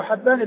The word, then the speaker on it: حبان: فحبان هو الفتح الهاء وليس بكسرها، وهو ابن هلال وهو ثقة حديثه عند أصحاب الكتب الستة.